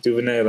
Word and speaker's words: DuVernay: and it didn't feel like over like Ava Ava du, uh, DuVernay DuVernay that's how